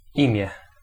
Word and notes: name: /neɪm/

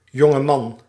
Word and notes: jeune homme: France; [ɛ̃ ʒœ.n‿ɔm]

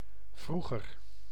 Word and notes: vroeger: /ˈvruɣər/

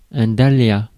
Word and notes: dahlia: French

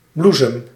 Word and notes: blomma: IPA: /blʊma/

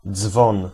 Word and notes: bel: [bɛl]

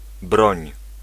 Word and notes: broń: [brɔ̃ɲ]

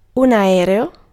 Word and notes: aereo: /a.'ɛ.reo/

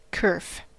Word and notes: kerf: American English; /kɜːf/

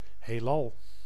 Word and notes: ciel: [sjɛl]; Paris

